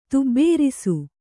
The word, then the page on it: ತುಬ್ಬೇರಿಸು: tubbērisu